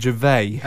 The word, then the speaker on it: Gervais: I always pronounce it Gervais. – Ah. – Because I think he's French. – Right. – So that's. That's the way I pronounce it. – Okay. – But I might be wrong on that, but it is spelt Gervais, like the comedian. – Okay. – Like Ricky.